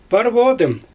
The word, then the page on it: parvoodym: Xyzyl